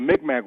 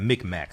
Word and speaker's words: Mi'kmaq: But the Mi'kmaq wrote Medunetter on tree bark. – How do you spell that, Mi'kmaq? How do you spell that? – M-I-C-M-A-C. – Okay, M-I-C-M-A-C.